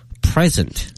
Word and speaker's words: present: 它叫 present。 – 重音在第一个音节、重音在第一个音节是 present, 表示目前的现有的。